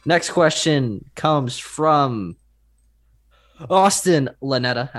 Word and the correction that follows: Ionetta: at Austin. Oh, Ionetta, my apologies.